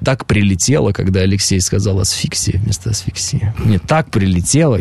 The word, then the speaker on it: асфиксия: Но нам нужно с тобой дальше, я так понимаю, асфиксия, правильно? Да, асфиксия, правильно. Я говорю, слета читал, неправильно поставил ударение.